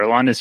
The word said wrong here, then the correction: Aroldis: And then I always say his first name wrong. How do you say it? – Aroldis.